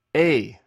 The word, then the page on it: ei: greek